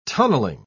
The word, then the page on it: tunnelling: British English